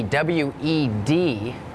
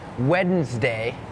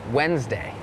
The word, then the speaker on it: Wednesday: โล่งอกเหรอลูกโล่งใช่ไหมเห็นแล้วถอนหายใจเฮือกเริ่มเลยก่อนเริ่มรายการพูดถึงคำว่าอะไรน้องๆจำได้ไหมคะ Silent letters ใช่ไหมคะนั่นก็คือตัวอักษรที่ไม่ออกเสียงแต่อย่างคำนี้ค่ะเป็นคำหนึ่งที่มี silent letters Wednesday it has that D W E D it's not wed Wednesday Wednesday D is silent